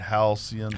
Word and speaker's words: Halcyon: Halcyon, H-A-L-C-Y-O-N.